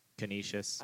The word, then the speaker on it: Canisius: Canisius.